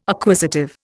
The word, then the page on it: acquisitive: Indian